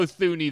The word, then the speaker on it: Thuney: And we haven't had a chance to talk about Joe Thuney yet. And there's – or Thuney, I did it! I did the Joe Thuney this time.